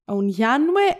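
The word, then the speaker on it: Aunjanue: η Ουνιάνουε θέλει να λέγεται έτσι, για τον King Richard.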